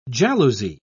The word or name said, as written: jalousie